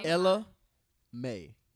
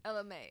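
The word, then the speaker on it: Ella Mai: Ella, is it Ella? Is that how you say her name? – Ella May. Get your shit together. – Ella May.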